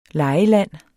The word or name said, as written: legeland